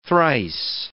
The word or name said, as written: thrice